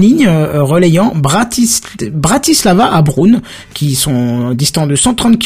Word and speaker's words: Brno: Alors ça s'écrit B-R-N-O, mais apparemment Apparemment, ça se prononce Brune.